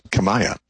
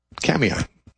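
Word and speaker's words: Kamiah: it doesn't look like it should be pronounced Kamiai. It looks like it should be pronounced Kamaya. It's K-A-M-I-A-H. I guez it's a Nez Perce Indian word, and they pronounce it Kamiai.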